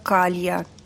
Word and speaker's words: Калла: В нашем мире слово «калла» имело бы испанские корни, как и многие из слов, которые ты помнишь по Мэджису, Роланд, и произносилось как «калья».